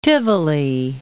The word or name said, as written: Tivoli